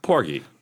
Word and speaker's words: Porgy: Is it Porgy or Porgy? Porgy. – It's Porgy.